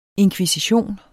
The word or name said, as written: inkvisition